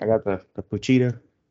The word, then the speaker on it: Pochita: It's Puchita, right? – Yeah, Puchita. – Okay, I was gonna say, don't tell me I'm butchering that name too. Or is it Bukita? – No. Puchita.